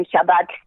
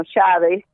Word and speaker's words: Shabbat: Shabbat, sino Shabbat, por ejemplo, o Yom Tov, sino Yom sino que en lugar de que las palabras fueran agudas, acentuadas en la última sílaba, las acentuaban en la penúltima.